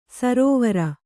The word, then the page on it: ಸರೋವರ: sarōvara